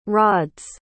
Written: rod's

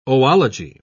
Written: oology

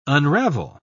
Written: unravel

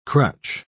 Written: crutch

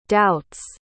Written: doubts

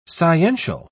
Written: sciential

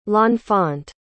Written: l'enfant

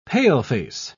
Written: paleface

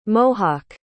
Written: mohock

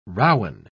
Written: rowen

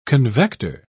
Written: convector

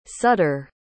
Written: sutter

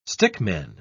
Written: stickman

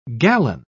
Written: gallon

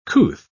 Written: couth

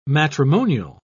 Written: matrimonial

